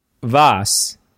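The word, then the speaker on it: Vos: I was actually going to make a way too early Worlds prediction and say that Voss, you know, you go, girl. She's going to win it. – Wait, Voss or Vosh? – Voss, as in the Spanish word for you go.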